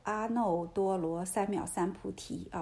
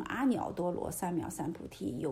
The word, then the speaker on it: 阿耨多罗三藐三菩提: 这个字啊念 no 阿耨多罗三藐三菩提啊，我以前这个念错了，更正一下，以前读成阿耨多罗三藐三菩提，有这样念的啊，但是正确的念法呢还是阿耨多罗三藐三菩提心 no 啊。